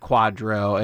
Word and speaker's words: QDRO: Q-D-R-O, pronounced Quadro, and it's basically a judicial order in the U.S.